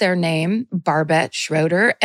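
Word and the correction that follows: Barbet Schroeder: and declared them to be a woman and said I was really proud that my favorite movie was directed by a woman. It is absolutely not a woman, Barbette, and it's actually pronounced Barbe Schroeder.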